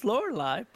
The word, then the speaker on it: Lorelai: Lorelei. You were close. It's Lorelei, but close.